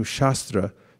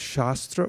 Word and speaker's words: shastra: The word can be pronounced as shastra or shastra. So as shastra, it means the knowledge, the body of knowledge, or a spiritual teaching, like the Bhagavad Gita. And when you say shastra, it's just a short A rather than a long A, then what you're referring to is a weapon like a sword.